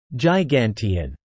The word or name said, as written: gigantean